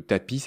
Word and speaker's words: tapia: Et puis on avait fini sur cette histoire de tapis, ça s'écrit tapia, mais ça se dit tapis,